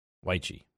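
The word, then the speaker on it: Wyche: NFL Network's Steve, how do you pronounce his last name? Weichy, that's right.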